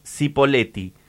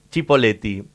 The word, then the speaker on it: Cipolletti: Tengo por acá, hola radio, Cipolletti dice, se pronuncia dependiendo de quién lo diga. Argento hablantes, Cipolletti. Italo parlantes, Cipolletti.